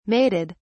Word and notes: mated: British English

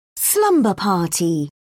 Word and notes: slumber party: British English

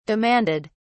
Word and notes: demanded: British English

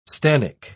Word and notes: stannic: British English

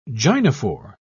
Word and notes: gynophore: British English

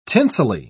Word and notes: tinselly: British English

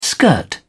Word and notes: skirt: British English

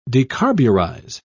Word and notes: decarburize: British English

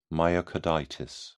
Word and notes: myocarditis: British English

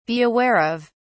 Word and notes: be aware of: British English

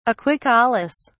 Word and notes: aquicolous: British English

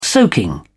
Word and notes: soaking: British English

